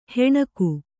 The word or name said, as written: ಹೆಣಗು